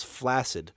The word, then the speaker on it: flaccid: I was just going to talk about how I learned how to properly pronounce flaccid. Flaccid. – I mean, it's not properly because it has nothing to do with Lake Placid. It's been pronounced that way for forever. But yeah, in the original language, it would have been with a double C like that.